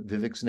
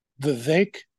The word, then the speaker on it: Vivek: How do you pronounce uh, Vivek's name? Vivek rhymes with cake, Ramaswamy. Vivek Ramaswamy.